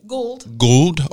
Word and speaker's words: Gould: Gould.